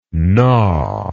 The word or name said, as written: gnawable